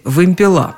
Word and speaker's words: вымпела: Хотите – вымпелы, хотите – вымпела. В таких случаях я, как правило, обращалась за советом к самому строгому арбитру, к словарю ударений Агеенко и Зарвы. Там действительно присутствовал всего один вариант с ударением на первом слоге «вымпелы», «вымпелов», «вымпелами».